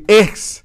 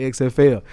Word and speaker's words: XFL: Because the XFL. – No, you didn't say that right. – My bad. Give it to us. – The XFL.